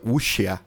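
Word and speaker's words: Wuxia: Isso pronuncia Uxia.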